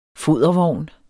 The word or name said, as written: fodervogn